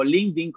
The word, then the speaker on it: LinkedIn: Eh, si me quieren encontrar, o pues en las redes yo estoy con mi nombre, en LinkedIn o LinkedIn, como realmente se pronuncia.